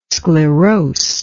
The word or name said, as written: sclerosed